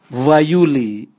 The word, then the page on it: vwaiuli: Raga